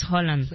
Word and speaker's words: Haaland: Oye, su pronunciación es Haaland. – Haaland, de hecho, ayer me pegó un tirante también con mi esposa hablando de eso. Ayer fue día de tirantes de todos los aspectos. – ¿Sí? ¿Ah, sí? – Es porque me decía... – Hubo estira y afloja. – Ajá, porque me decía, realmente es Haaland. – Ajá. Y yo, ¿ella te dijo eso? – Ajá, y yo por dentro, cállate tu madre. – No. Realmente, discúlpame, tienes que pronunciarlo mejor, es Haaland.